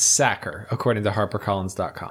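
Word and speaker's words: Sachar: It should be pronounced Sacker, like someone who tackles quarterbacks, or someone who stuffs potatoes into sacks.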